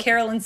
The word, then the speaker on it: Carolyn: Carolyn Sagal on pretty much anything with a Y.